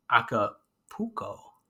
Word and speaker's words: Acapulco: But like when it wraps up, and it is Acapulco.